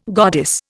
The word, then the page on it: goddess: Indian